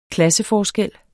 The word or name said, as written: klasseforskel